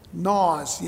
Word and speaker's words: gnaw: Gnaws, you know, G-N-A-W, gnaw. Gnaw, but don't pronounce the G.